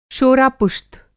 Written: शोरापुश्त

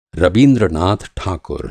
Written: रबीन्द्रनाथ ठाकुर

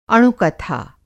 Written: अणुकथा

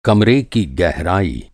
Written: गहराई

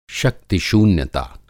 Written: शक्ति शून्यता

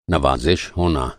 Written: नवाज़िश

